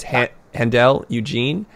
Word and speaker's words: Handel Eugene: It's Handel Eugene, and it's spelled H-A-N-D-E-L, Eugene, E-U-G-E-N-E, Eugene.